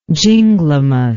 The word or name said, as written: ginglymus